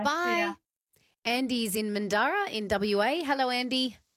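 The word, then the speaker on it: Mandurah: It's an indigenous name, and it's pronounced Mandura.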